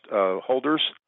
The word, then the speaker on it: hodlers: is they call themselves hodlers, but it's pronounced holders, meaning you buy Bitcoin and you hold on for dear life.